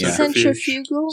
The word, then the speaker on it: centrifugal: Did you repack up your centrifugal? I don't know how to pronounce that word. Somebody give me centrifugal.